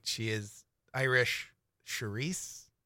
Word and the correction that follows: Saoirse: right? Roynan? – Saoirse.